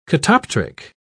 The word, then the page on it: catoptric: British English